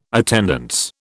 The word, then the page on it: attendance: British English